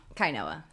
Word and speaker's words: Kainoa: Kainoa. That's my middle name. – Kainoa? Spell it? – K-A-I-N-O-A.